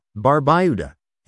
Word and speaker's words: Barbuda: Antigua, pronounced Antigua, and Barbuda, Barbuda, is located in the heart of the Caribbean Sea.